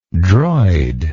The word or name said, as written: droid